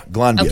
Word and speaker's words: Glanbia: And with Glanbia, is that how you say it? – Yeah, Glanbia, okay.